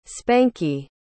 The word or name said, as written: spanky